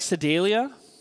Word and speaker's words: Sedalia: Is that like Sedalia? Did I say that name right? That's how you say it.